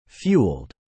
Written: fueled

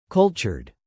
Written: cultured

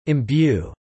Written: imbue

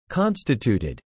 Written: constituted

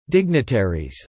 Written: dignitaries

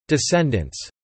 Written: descendants

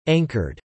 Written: anchored

